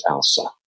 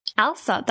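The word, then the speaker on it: Ailsa: So I've got a precious little grandson named Jethro and a precious granddaughter who was born about 6 months ago named Alsa. – Alsa, that's a really unique name, but I like it.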